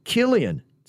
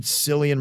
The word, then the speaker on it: Cillian: Cillian is actually pronounced Killian. It's not a big deal unless you meet him, but okay, I never knew that. I always heard Cillian Murphy, but I guess it would be Killian Murphy with a C.